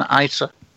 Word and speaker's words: ICER: Do we call it ICER? Is that that's perfect pronunciation, ICER.